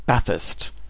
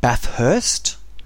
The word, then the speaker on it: Bathurst: People that are into cars, people that are into, I guess, their V8s, would know all about Bathurst, and it's pronounced Bathurst, not Bathurst.